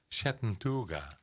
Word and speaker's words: Chattanooga: En Cody Landrus Gibson is een singer-songwriter uit Chattanooga.